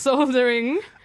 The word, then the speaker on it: soldering: As some people say, soldering. – Yes, our American cousins do. I don't know why they don't pronounce the L in the middle. I've never got that.